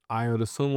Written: Ayo Dosunmu

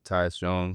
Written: tyus JONES

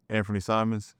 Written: Anfernee Simons

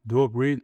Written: Duop Reath

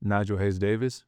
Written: Nigel Hayes-Davis

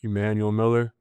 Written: Emanuel Miller

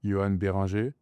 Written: joan BERINGER

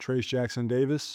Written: Trayce Jackson-Davis

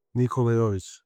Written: nikola JOVIC

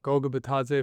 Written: Goga Bitadze

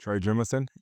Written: Trey Jemison III

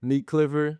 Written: Nique Clifford

